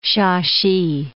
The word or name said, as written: Shashi